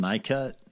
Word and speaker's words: Nicut: Nicut? Is it pronounced Nicut? N-I-C-U-T? – Yeah.